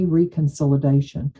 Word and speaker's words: reconsolidation: What is the, Julian Shore uses, you know, boundary work is memory reconciliation. Am I saying that right? Memory reconsolidation. – Yeah, reconsolidation. That's right.